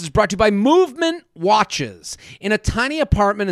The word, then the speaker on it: MVMT: With fair prices, unexpected colors, and clean original designs, Movement, MVMT, pronounced Movement, grew into one of the fastest growing watch brands, shipping to over 160 countries across the globe.